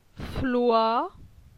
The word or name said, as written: Floi